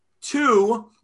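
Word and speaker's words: two (ט״ו): Two is just a short way of saying number 15, because you spell it with a tet and a vav. So you phonetically pronounce it as two, like Tu B'Shvat, right?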